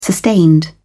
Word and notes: sustained: British English

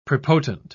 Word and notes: prepotent: British English

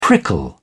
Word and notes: prickle: British English

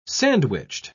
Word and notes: sandwiched: British English

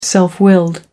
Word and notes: self-willed: American English

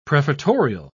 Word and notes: prefatorial: British English

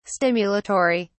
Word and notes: stimulatory: British English

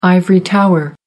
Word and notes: ivory tower: American English

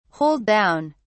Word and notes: hold down: British English

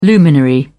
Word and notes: luminary: British English

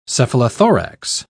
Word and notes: cephalothorax: British English

